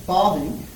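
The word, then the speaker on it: Bavinck: Herman Bobbing, that's how you say it, the Dutch Reformed theologian from the late 1800s to the early 1900s, he defines it this way.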